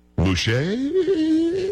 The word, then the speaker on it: Mushait: I don't know how to say this. Mushait. Mushait? Can we say it? We're going to go with that. Paul, are we good with that? You're not done with that, are we? – Say it the way you need to say it. – Mushait? – Yes. – Yes, that's good.